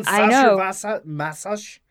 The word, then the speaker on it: Sacher-Masoch: Leopold von Sacher-Masoch. – I pronounced it totally the wrong way. He's Austrian German. – Leopold von Sacher-Masoch.